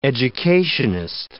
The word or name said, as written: educationist